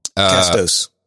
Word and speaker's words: Castos: We have two gentlemen on from an outfit called CastOS. That's how I pronounce it. I may be wrong. – Castos. – Is it Castos? I like CastOS, but okay, Castos.